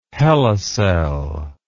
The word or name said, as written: hela cell